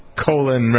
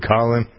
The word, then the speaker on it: Colin: I always like to say it not the way he likes to pronounce it. Colon rather than Colin.